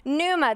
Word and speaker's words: pneuma: Pneuma, the P is silent.